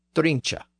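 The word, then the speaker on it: drunche: So it has an E at the end, pronounced eh, drincha.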